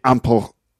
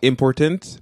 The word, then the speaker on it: important: Yeah, because that would be important. – Yeah. – But important. – Mm. – So in English, the stress is on the second syllable. – Not important, but important. – Important.